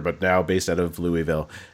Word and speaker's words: Louisville: It's Louisville. – Oh, I should know better. That's true. – Right? Like Louisville. New Jersey, Louisville.